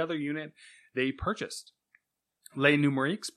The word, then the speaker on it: Les Numériques: How do you pronounce that? – Les numéro 15. That's more Spanish, actually. – Numerics. Yeah, that's what I was thinking. Numériques. – Yes. – Les numériques. Yeah. French tech website, Les numériques.